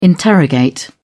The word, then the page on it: interrogate: British English